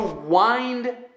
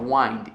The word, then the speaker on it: wind: E nota que a pronúncia é wind e não wind. Wind seria vento. Wind seria ação de girar uma, sei lá, uma mola num brinquedo assim. To wind up a toy. Or to wind up a person is to provocar, zangar a pessoa.